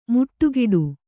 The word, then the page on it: ಮುಟ್ಟುಗಿಡು: kannada